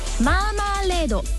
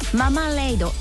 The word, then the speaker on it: マーマレード: ただやはりこれアルファベットですのでマーマレードと読んだりママレードって読んだり、これも別に間違いではないということなんですね。